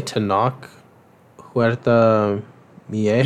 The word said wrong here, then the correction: Mejia: Yeah. – Mejia? – Yes.